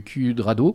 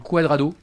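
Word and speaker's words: Cuadrado: Franck Margerin et de Marc Cudrado. Quadrado.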